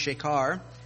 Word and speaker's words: shekar: And shakar, S-H-E-K-A-R, pronounced shakar, is an intoxicant or intensely alcoholic liqueur or liquor, strong drink or strong wine.